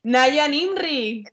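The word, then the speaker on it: Najwa Nimri: Y además es que tenía un nombre muy raro, bueno, para mí era muy raro, era, que no sé cómo se pronuncia, me da un poco de coraje pronunciarlo mal, Nahua Nirmi. – Naya Nimri. – Naya. – Naya Nimri.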